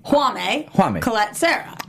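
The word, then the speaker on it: Jaume Collet-Serra: So we have decided collectively, Ryan, Mike, and myself, that his first name is pronounced Juame Colette Serra. – Yes, because of being the J being silent.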